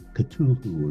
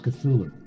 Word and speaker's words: Cthulhu: And Bob said, that's not how it's pronounced. – Interesting. – Me being a smart ass, I didn't say that. I said it something another way, Cthulhu or something like that. And he said, no, it's pronounced Cthulhu. And I said, well, how do you know? And he said, because I asked Lovecraft.